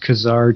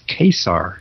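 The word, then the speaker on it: Ka-Zar: I always thought it was pronounced Kazar too, but now, looking at the very first splash panel of the first page, there's a little arrow that says pronounced Kesar. – Man, you're jumping way ahead. I wanted to talk about this cover here with Kazar. What's that? – We can just jump backwards. – Oh, I'm sorry. Yes. Yes, Kesar. They go out of their way to tell us that it's pronounced Kesar.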